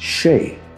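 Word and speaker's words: sìth: It derives from the ancient Celtic word, pronounced She.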